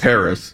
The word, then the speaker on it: Harrah's: Have you ever stayed at Harrah's or how do you pronounce Harrah's. – It? – Harrah's.